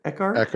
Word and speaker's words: Echard: Is that how you say it? Eckhart. – Yeah, E-C-H-A-R-D.